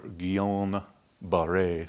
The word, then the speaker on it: Guillain-Barré: Leading possibility right now is Guillain-Barré syndrome, or Guillain-Barré syndrome, as it's supposed to be pronounced, but nobody does.